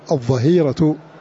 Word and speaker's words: الظهيرة: ثم قوله كذلك في هذه المنظومة الظهير, الظهيرة شدة الحر, ومنه قوله تعالى وحين تضعون ثيابكم من الظهيرة, فالظهيرة بالضاء ولا تنطق بالضاد, وحين تضعون ثيابكم من الظاء تقع بالضاء لا تنطق بالضاد, وحين تضعون ثيابكم من الظهيرة في سورة النور آية 58.